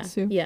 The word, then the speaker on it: Sioux: So he saw that he was a Sioux, or that's how you say it, right? – Yeah, Sioux. – Sue.